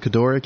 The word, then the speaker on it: Kedora: Kedora? K-E-D-O-R-A? – Yep.